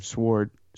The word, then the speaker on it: SWORD: I'm saying it's you know, Nick Fury, director of Sword. – It just happened. – W is silent, Aaron. Sword, and that's like the space version of Shields.